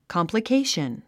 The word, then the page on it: complication: kὰmpləkéiʃən; カンプラケイション